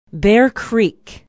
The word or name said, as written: bear creek